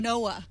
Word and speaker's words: quinoa: Quinoa. – It's quinoa. – They don't even know what it is. – Q-U-I-N-O-A.